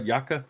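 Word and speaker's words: yakka: Am I pronouncing that right, Yaka? – Pronunciation seems on point.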